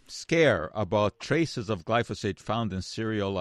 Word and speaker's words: glyphosate: Glyphosate. Glyphosate, that's pronounced.